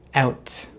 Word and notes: out: American English

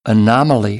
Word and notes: anomaly: American English

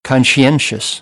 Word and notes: conscientious: American English; ɑː

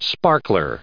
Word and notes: sparkler: noun

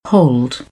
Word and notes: hold: British English

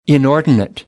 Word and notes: inordinate: American English; ɔːr

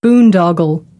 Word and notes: boondoggle: American English; ɑː

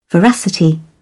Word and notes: veracity: British English

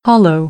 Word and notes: hollow: American English; oʊ

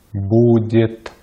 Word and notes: будет: Russian

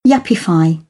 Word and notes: yuppify: British English; ɪ

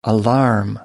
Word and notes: alarm: noun

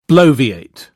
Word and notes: bloviate: British English

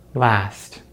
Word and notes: last: American English

